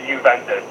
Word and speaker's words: Juventus: It's the J silent, so it's Juventus.